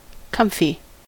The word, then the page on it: comfy: American English